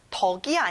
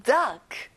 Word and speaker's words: duck: 덕이 아니라 duck. 잘했어요.